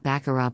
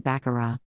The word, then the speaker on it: Baccarat: An enjoyable reality you require to find out about Baccarat besides that the T is silent, of course, it's obvious, Baccarat dash is likewise the truth that the word means absolutely no or nothing.